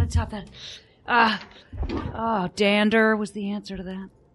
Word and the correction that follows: Donder: No, Donder. Not Dander.